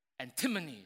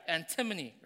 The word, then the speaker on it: antimony: It rhymes with chimney. There you go, Lynn. You got it? Antimony. That's right. Antimony, right?